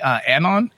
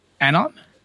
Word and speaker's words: Anon: Anon. – Okay. – How would you have said this? – Anon. – Interesting. – Like anonymous.